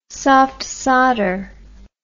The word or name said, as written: soft sawder